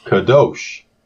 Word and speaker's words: Kadosh: And along the way, they were pronouncing the word for holy, Kadosh. But it's really pronounced Kadosh.